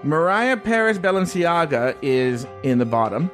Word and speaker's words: Mariah Paris Balenciaga: Well, first of all, thank you for saying her name correctly. Mariah Paris Balenciaga.